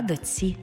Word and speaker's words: дочці: Пишемо «чці», а вимовляємо «ці». Наприклад, не «дочці», а «доці». Не «річці», а «річці».